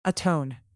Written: atone

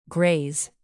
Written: graze

